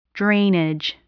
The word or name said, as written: drainage